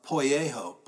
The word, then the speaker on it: poieo: That word redeemed is uh, poieho. Poieho, that's spelled P O I E O in English.